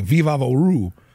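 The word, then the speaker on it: Vicks VapoRub: This like I am like if you're from South Florida and you grow up with Vicks Vapo Rub, it's like Viva Voru, which is like the Latin pronunciation here in Miami.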